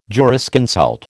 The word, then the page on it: jurisconsult: American English